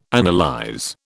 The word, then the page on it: analyze: British English